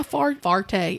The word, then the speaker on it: forte: It's not my forte. It's not my my forte. How do you say that word? – Forte. – Forte. Oh, like a four. Like fart. Forte.